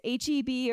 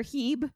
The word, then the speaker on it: H-E-B: And whether it's H-E-B or Heeb, it depends, apparently it depends on where you're from, how you pronounce it.